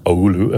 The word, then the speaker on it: Oulu: in a in a place called Oulu, if I pronounced that correctly.